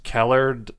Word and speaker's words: Kellard: I think it's like K-E-L-L-A-R-D or E-R-D. – Kellard. – I don't know. You're stupid when you... – Like Howard? Like the end of Howard? – Almost, yeah.